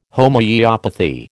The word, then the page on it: Homoeopathy: American English